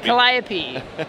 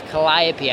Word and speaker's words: Calliope: Calliope. I want to say Calliope now, but it's Calliope. I've got it now. I got it. – That's like the European way.